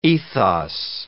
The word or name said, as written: ethos